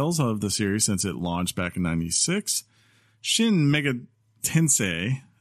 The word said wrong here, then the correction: Shin Megami Tensei: Uh, I think I said that right. Shin Megami Tensei. I almost got there.